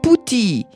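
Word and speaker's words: puti (菩提): Agora, esta árvore ficus religiosa pronuncia-se em chinês como puti.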